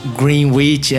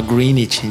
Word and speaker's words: Greenwich: Greenwich, é exato, que você não pronuncia esse W, você fala Greenwich.